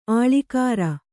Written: ಆಳಿಕಾರ

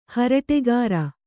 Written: ಹರಟೆಗಾರ

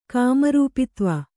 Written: ಕಾಮರೂಪಿತ್ವ

